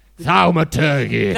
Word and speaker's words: thaumaturgy: I'll use a bit of thaumaturgy. How do you say it? – Thaumaturgy.